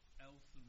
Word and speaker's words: Elson: My name is Peter Elson, which is spelled E-L-S-O-N. – Peter Elson, E-L S-O-N.